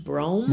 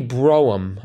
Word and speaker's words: Brougham: And that's pronounced brome. – Brome. Mm-hmm. – But it's spelled B R O U G H A M. – Yes. – It might be Broham.